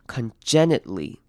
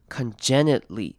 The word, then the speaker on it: congenitally: 那这个字要特别注意的是，这边 it 会念成 congenitally, congenitally, 所以会念成短母音 i。然后 t 你可以省略或是不省略，那两种念法也会不一样。你可以念成 congenitally, 或者是 congenitally, 两种声音都可以。